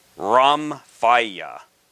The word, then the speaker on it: rhomphaia: And that word is Ramphia. And that is spelled R-H-O-M-P-H-A-I-A.